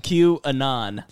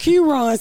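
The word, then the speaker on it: QAnon: Q-anon, like anonymous. – What? You know, I really didn't know why the white people holding up Q-ron sign.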